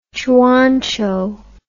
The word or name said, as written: Zhuang Zhou